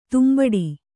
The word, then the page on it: ದಂಬಡಿ: Kannada